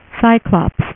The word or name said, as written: cyclops